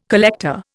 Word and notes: collector: Indian

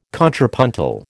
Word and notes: contrapuntal: American English

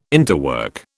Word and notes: interwork: British English

